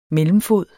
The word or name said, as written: MELLEMFOD